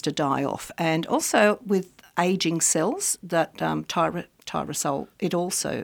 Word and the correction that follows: tyrosinase: Tyrosinase. – Tyrosinase, yeah. That's how you say it.